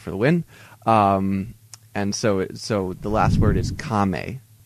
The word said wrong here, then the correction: kame: Game. It's kind of a G instead of a K. It's again, we have an unaspirated K, so it's not the harsh K. It's a softer K.